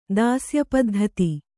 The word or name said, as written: ದಾಸ್ಯಪದ್ಧತಿ